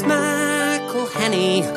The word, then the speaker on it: McElhenney: It's McElhenney, McElhenney.